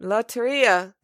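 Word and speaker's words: Lotería: And got a chance to hang out with the whole team, as well as play some Loteria. – Yep, Loteria. Did I say it right, Renee? – Yep. – Loteria.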